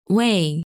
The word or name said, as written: Wei